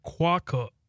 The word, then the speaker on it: quokka: Okay, so it's quokka. Quokka. – Okay. – Quokka. That's how you say it.